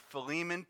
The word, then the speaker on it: Philemon: it's spelled P-H-I-L-E-M-O-N. Philemon, P-H-I-L-E-L-E-M-O-N, Philemon.